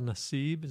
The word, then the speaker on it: Nassib: Carl Nassib, is that how you pronounce his last name? – Yeah.